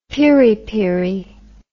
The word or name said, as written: piripiri